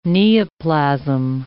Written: neoplasm

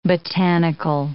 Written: botanical

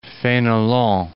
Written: Fenelon

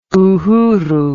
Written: uhuru

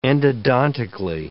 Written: endodontically

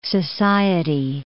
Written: Society Islands

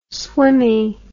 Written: swimmy

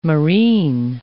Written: marine

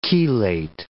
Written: chelate

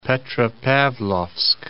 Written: Petropavlovsk

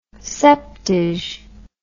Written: septage

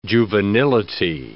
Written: juvenility

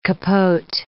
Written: capote

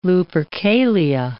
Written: Lupercalia

